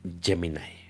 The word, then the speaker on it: Gemini: la pronunciación en inglés, mamilas, es Gemini.